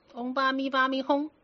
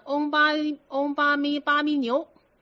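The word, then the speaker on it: 唵嘛呢叭咪吽: 嗡巴咪巴咪哄啊。那有个老太太，那个哄字不会念，她念成嗡巴咪巴咪牛，因为她左边是个口嘛啊。右边是个牛嘛，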